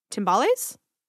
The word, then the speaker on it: timbales: I wanted to bring the Latin element into that record by using, I don't know how to say this instrument, timbales? – Okay. – Sure. – Is that how you say it? – Yeah, that sounds right.